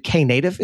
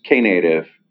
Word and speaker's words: Knative: And we just saw at Google Next that I believe Knative is how it's pronounced.